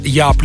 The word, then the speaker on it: Jaap: Ну а достойным украшением передачи станет специальное интервью, которое мне дал голландский музыкант Яп Ликхард. Его имя еще и произносят как Джап Ликхард.